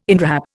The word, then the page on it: inwrap: Indian